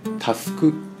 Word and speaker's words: 翼: 人のためにをモットーに、歌でも介護でもたくさんの人を幸せにしたい、そんな思いでアーティスト活動を行う、タスク、翼と書いてタスクと読むそうです。